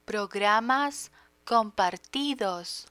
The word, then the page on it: programas compartidos: Spanish